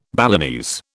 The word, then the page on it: Balinese: British English